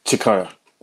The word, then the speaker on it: Cikaya: Is it Cikaya? Did I pronounce that right? – Cikaya. – Cikaya, yeah, bro.